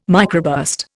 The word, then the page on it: microburst: Indian